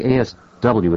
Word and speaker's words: ASW: No, ASW is said ASW. MAD is called MAD. – Yeah, I think ASW would be hard to pronounce.